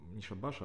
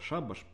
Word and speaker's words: шабаш: Вот на тему того, что у Белого как-то вроде странно, человек вообще грамотный, как будто не мне его учить надо, что не шабаш, а шабаш по литературному русскому языку, а на худой конец шабат, если уж ударение на второй слог делать.